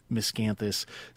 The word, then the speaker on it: Miscanthus: All right, Emily, for those of us who are not as familiar with Miscanthus, say more than I did in the introduction just then about what it is.